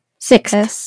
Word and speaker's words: sixth: So, so I'll spell it. S-I-X-T-H. Sixth. – Sixth. – Sixth. Yes. – Yes. But like saying it fast, you don't really say the T-H very pronunciated. – Right. Those the sixth, the X and the H.